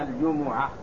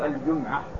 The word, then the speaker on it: الجمعة: الجمعة هو المراد به اليوم المعروف من أيام الأسبوع، ونطقها العربية هكذا: الجمعة. يا أيها الذين آمنوا إذا نودي للصلاة من يوم الجمعة، بضم الجيم والميم. وبعضهم يسكن الميم ويقول الجمعة، وبعض أهل العلم أيضا يقول باسمها، يقول الجمعة، يعني اسمها الجمعة لأنها تجمع الناس ويجتمع الناس فيها.